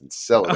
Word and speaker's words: Enceladus: And because there's some moons in the universe like Europa, which is around Jupiter, and Enceladus, which we've been butchering the pronunciation of that for a few episodes, but that's how it's pronounced, Enceladus.